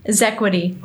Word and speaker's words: Xequity: Xequity. – Okay. – Like, it sounds like a Z, but it's an X.